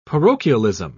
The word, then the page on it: parochialism: British English